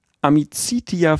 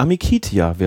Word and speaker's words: Amicitia: Partie zwischen dem TSV Amicitia Viernheim 2, was natürlich so ausgesprochen eigentlich falsch ist. Wir als ähm, Sprachpodcast wissen, im Lateinischen sagt man das umgangssprachlich eher anders nicht nur, nicht nur umgangssprachlich. Amicitia wäre richtig.